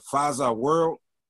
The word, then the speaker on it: Faza World: Is it Faza World? – Yeah, Faza World.